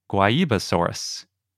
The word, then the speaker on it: Guaibasaurus: It's a dinosaur from Brazil, so we're going with the Portuguese pronunciation. – Yeah, it's spelled G U A I, Basaurus, if you can't tell by how we're pronouncing it.